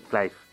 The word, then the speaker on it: Clive: y hemos llegado al acuerdo de que es Sir Clive Sinclair. Cliff. No, Cliff. Cliff es lo que decía yo, mal, para el principio, pero bueno.